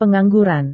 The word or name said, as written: pengangguran